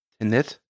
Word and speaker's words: Tenet: Ich glaube einfach äh, Tenet. Tenet? Ja. Ja, hinten wie von vorne, ne?